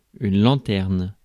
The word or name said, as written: lanterne